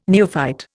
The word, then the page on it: neophyte: Indian